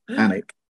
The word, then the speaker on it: Alnwick: It is Anik, I think, even though it's not spelt that way. It's Alnwick. – Alnwick, yeah. Anik.